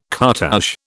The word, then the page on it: cartouche: British English